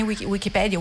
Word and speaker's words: Wikipedia: mi rimproverano di aver detto Wikipedia con l'accento sulla E, eh, mentre diciamo il, l'assonanza con l'enciclopedia italiana vorrebbe l'accento sulla I, voi come dite?